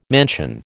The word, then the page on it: mansion: American English